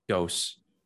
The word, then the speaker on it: Gose: Anthony, how do you pronounce his last name? – Gose. – Anthony Gose. Yeah, Anthony Gose.